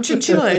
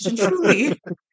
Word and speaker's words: Cianciulli: It's not chinchilla, it's chinchuli. – Chichuli, chinchuli. I can't say it. Okay, so Leonardo Chinchuli, Chinchuli, better known as the soap maker of Correggio.